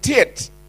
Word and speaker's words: tet: Rabbinic commentary says this, that number 9, or tet is the Hebrew pronunciation, alludes to mud, the physical matter from which man's body was created and to which he will return.